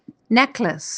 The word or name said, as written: necklace